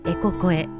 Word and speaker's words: ECOCOE: は 4000 万曲もの音楽が聴き放題のアプリ Spotify でプレイリストを公開していますエコ声という名前でプレイリストを公開していますのでぜひ聴いてみてくださいスペルは ECOCOE ですエコ声と読み